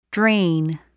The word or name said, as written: drain